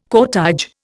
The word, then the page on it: cortège: Indian